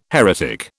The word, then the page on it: heretic: British English